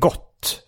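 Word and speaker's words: GWOH!: Eller jag via Bromander har fått reda på att alltså det står G-O-O-H. G-W-O-H utropstecken. Ja, och det är inte de frågade hur skulle de frågade grundaren hur det skulle uttalas för det var många som uttalade go eller go. Men han sa att det skulle vara som att det är så on the go. – Men han menade att det skulle låta som gott fast bara go.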